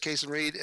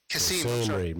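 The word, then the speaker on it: Kasim Reed: So that's Atlanta's mayor is Kasem Reed and the spokesman. – No, Kasem Reed, man. You're not going to play this town again if you don't get his name right, dude.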